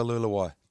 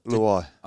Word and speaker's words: Luai: Is it Luai or Luluai? – Luai. – Oh, okay. Luai. I thought you might have added an extra syllable there, but that's all right.